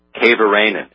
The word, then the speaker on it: Kaivarainen: And if you look up the name Alexander, and I'm going to spell this for you, K-A-I-V-A-R-A-I-N-E-N, Kavaranen is how you say this.